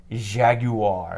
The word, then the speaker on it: Jaguar: we talked about the Atari Jaguar.